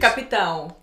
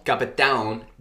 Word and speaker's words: Capitão: And eh, how do you say captain in Portuguese? – Capitão. – Capitão.